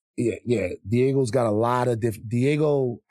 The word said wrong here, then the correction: Diageo: It's Diageo.